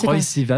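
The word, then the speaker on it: Troye Sivan: Pour le moment, alors on va rester à musique en attendant de leur poser la question et on écoute Troy Sivan, c'est ça? Est-ce que je l'ai prononcé Troy Sivan.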